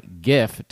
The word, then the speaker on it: GIF: I'm going to just say capital G, capital I, capital F, originally stood for Graphics Interchange Format. So it's a hard G. Thank you for coming to my GIF talk.